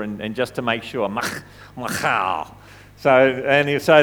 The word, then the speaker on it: Michal: Firstly, we see that David loved Saul's daughter, Michal. You've got to say it with a ch. Apparently, that's the way, Michal. So that's how her name is pronounced.